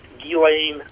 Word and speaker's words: Ghislaine: And I still, Barry, how do you say, is it Ghislaine? Is that how you say her name? – You can, well, television has, you know, says a million different things, but it's pronounced Ghislaine Maxwell.